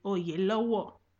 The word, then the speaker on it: Oyelowo: My fave at the moment is David Oyelowo. That's how you say it, right, Ethan? Okay. – Yes, that is.